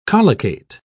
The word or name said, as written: collocate